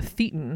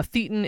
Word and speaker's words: thetan: They believe that the human individual is actually a thetan. Am I saying that right? – I think so.